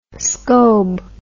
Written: scobe

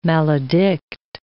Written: maledict

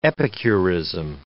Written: epicurism

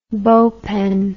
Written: bow pen

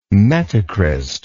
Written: metacryst

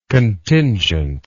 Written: contingently

